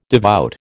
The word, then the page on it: devout: American English